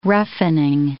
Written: roughening

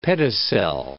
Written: pedicel

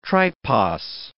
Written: tripos

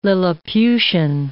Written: Lilliputian

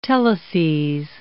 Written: teleses

